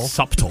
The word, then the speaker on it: subtle: And you don't pronounce the B in subtle. – Subtle.